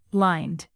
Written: lined

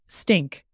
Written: stink

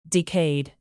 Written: decayed

